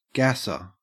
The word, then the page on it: gasser: Australian English